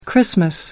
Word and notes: xmas: American English

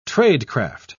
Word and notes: tradecraft: British English

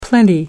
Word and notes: plenty: American English

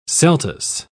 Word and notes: celtuce: British English

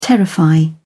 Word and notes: terrify: British English

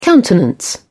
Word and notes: countenance: British English

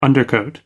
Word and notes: undercoat: American English

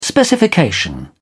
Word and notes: specification: British English

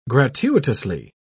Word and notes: gratuitously: British English

